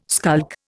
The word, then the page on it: skulk: Indian